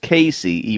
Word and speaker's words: Cayce: I want you to Google Wynn Free, which is W-Y-N-N space F-R-E-E. And then I want you to Google Edgar Casey, which is E-D-G-A-R space C-A-Y-C-E. Now, it's pronounced Casey, even though it looks like C-A-Y-C-E.